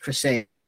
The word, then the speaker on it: Criseyde: Her name would have been pronounced Criseyde, and that's the way it works in the meter. That's the way it's rhymed.